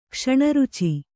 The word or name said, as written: ಕ್ಷಣರುಚಿ